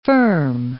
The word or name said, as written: firm